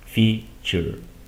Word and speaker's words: future: Te compartí también la estrategia en el curso de pronunciación que esta terminación ture normalmente la vas a pronunciar como si estuvieras viendo la C y la H. Future. ¿Ok? Futuro, future. Feature, característica. ¿Ok? Así es como suena.